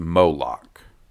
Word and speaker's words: Moloch: Lastly, the name of two important characters are the Big Bad, according to the Lego set, is Moloch, pronounced Moloch.